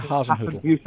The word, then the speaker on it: Hasenhüttl: Is that the right pronunciation? – Yeah, I think so, yeah. Hasenhüttl.